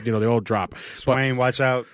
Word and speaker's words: Swaim: The tight end for your uh, Swaim. Swaim, right? – Swaim.